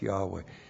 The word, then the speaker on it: Yahweh: So holy, no Orthodox Jew would ever pronounce that, which would be pronounced Yahweh. No, they looked at that and read Adonai.